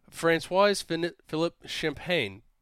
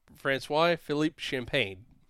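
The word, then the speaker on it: François-Philippe Champagne: Canada's industry minister, Francois Philippe Champagne, said in a tweet on Monday. I know I said that name wrong, but Francois Philippe Champagne.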